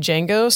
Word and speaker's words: Django: So Bixley is on Twitter at Bixley Django. So that's B-I-X-L-Y. And then there's a silent D in there. So it's D-J-A-N-G-O, Bixley Django.